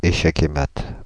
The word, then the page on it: échec et mat: /e.ʃɛk‿e mat/; [e.ʃɛk‿e mat]